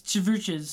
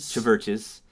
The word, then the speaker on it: CHVRCHES: And for those of you who have never heard of the band, Churches spelt with a V instead of a U. So C-H-V-R-C-H-E-S. – It's Chverches. – Chverches.